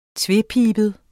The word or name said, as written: tvepibet